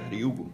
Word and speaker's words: Ryugu: Y así se escribe Ryugu. R-Y-U-G-U.